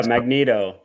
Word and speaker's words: Magneto: Yeah, Magneto.